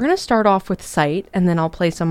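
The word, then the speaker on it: Sÿte: And Sight, like, S-Y-T-E with the two dots. It's pronounced Sight.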